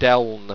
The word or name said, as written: deln